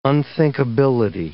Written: unthinkability

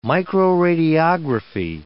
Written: microradiography

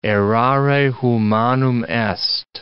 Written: errare humanum est

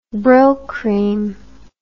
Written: Brylcreem

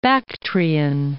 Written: Bactrian

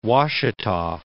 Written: Washita

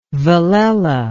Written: velella

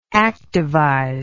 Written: activization

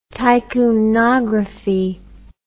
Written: tycoonography

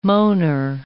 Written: moaner

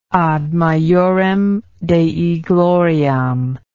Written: ad majorem dei gloriam

